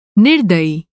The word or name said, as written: निर्दयी